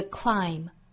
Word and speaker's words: climb: 那这个时候其实是元音和元音连读加 y the other side。最后 it's the climb， climb 这个词还是那两个要点哈、啊，一个是辅音连缀，一个是尾部 b 不发音。 It's the climb, climb.